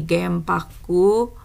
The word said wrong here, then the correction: 原爆: Eh, genbaku eh, cara bacanya adalah Gembaku.